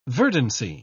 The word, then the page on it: verdancy: British English